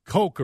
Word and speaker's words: Koch: So your father was talking about how the Twins had gotten after Koch, but he mispronounced it. And then Guardy, in a very funny way, said, "Yeah, we whacked Coke around pretty good."